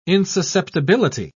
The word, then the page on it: insusceptibility: British English